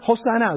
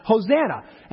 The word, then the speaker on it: Hosanna: Hosanna is really how it's supposed to be pronounced. Hosanna.